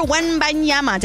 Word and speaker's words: Wembanyama: Victor Wembanyama. Did I say that right? – Yeah, that'll pass. – That'll pass. Okay.